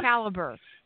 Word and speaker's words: Calibre: Calibre? – Calibre. Who knew? – I cut out the Calibre. Okay. – The creator commented on his blog about it.